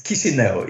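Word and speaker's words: Chișinău: It's Chisinau.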